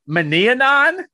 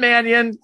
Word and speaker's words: Mannion: Sean Manianon. I know it's Manianon. I just wanted to mispronounce it for Scott's enjoyment. Manianon? Sean Mannion, Kellen Monday.